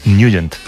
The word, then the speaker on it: Nugent: Pero la pronunciación correcta es Nugent. – Te fuiste a Washington y descubriste que era Nugent.